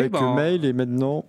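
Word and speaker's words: Mháol: On enchaîne avec un groupe irlandais qui sort son premier album, c'est le groupe Mail. Alors, ça s'écrit M-H entre parenthèses A-O-L.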